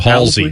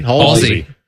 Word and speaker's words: Halsey: Halsey? Am I saying that right, Sleepy? – Oh, Halsey. – Halsey.